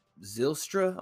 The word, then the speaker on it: Zylstra: Zach Zylstra, I want to say that's how it's pronounced, 44 yards and a touchdown.